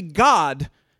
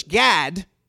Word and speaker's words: Gad: See, in Hebrew, it's actually God. God. The Gad is normally pronounced in English, Gad. But in Hebrew, it's God. But I know that would be very confusing to English-speaking folks. So we'll just say Gad.